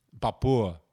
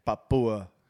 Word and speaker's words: Papua: Wait, say it again. – Papua. – Is that how you say it? – Yeah. – Papua.